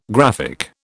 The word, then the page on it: graphic: British English